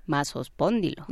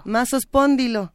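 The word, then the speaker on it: Massospondylus: ¿Cómo se pronuncia este dinosaurio? Masospóndilo. Masospóndilo.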